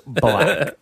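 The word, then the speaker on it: 6LACK: Pronounced black.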